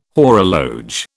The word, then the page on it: horologe: British English